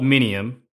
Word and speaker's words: Minium: And so the company is called Minium.